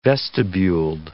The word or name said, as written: vestibuled